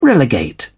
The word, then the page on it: relegate: British English